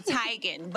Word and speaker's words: Teigen: She told y'all years ago to pronounce it Teigen, but okay.